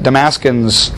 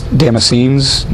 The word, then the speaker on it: Damascenes: He says in Damascus, the governor under King Aretas was the king of Arabia, under King Aretas, had the city of Damascenes. but that's not how you say it, Damascenes, it's Damascenes.